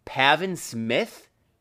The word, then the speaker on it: Pavin: but much better, Pavin Smith. What the fuck is that? I'm assuming I'm pronouncing it right. It's literally just Gavin with a P.